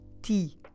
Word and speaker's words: ti: So this time there is nothing nasal about the word tea. It will be just like the English word tea, as in a cup of tea.